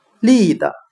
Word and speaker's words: lead: 我们可以用谐音法来记这个单词 lead，它的发音很像汉语的立德，站立的立，品德的德。